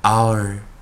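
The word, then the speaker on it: hour: 第二个 h h 我们念的是呢 h，注意啊，h 不发音，h 不发音，我们称 h silent。 our our our。第三个发音同音字啊。一样啊，我们看 h o u r 跟 o u r 啊，其实 h 不发音，所以 h o u r 跟 o u r 发音都一样。r r r 啊，这要小心一点啊。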